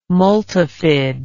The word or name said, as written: multifid